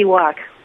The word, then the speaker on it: Wauk: Anything for our community is CC Walk, but spelled with W-A-U-K, not W-A-L-K.